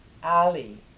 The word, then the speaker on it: ali: Round its center of the lotus, round its center, white ali, A-L-I, long A. Clockwise. Then red Kali, long A, K-A-L-I, counterclockwise, then the heart of dependent arising, blue and I recite them in snake-like succession.